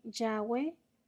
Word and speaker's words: Yahweh: Entonces es más probable que Yahweh o Jehová con Y sea la pronunciación más correcta, este, no Jehová con J.